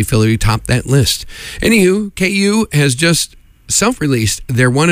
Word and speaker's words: Queue: Next up is a nice little tune from a band called KU, but it's spelled Q-U-E-U-E.